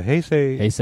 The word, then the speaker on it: Heisei: I thought those were the Hensei? – No, the Heisei. I'm probably mispronouncing it.